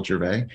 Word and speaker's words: Gervais: So at Michael Gervais, and the last name is spelled G E R V as in Victor, A I S as in Sam. So Michael Gervais.